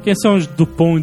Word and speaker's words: Dupont: No Brasil eles vieram com o original que foi lá de fora, do original mesmo, que é Dupont e Dupont. – É Dupont com T e Dupont com D, mas você não pronuncia a letra final, então...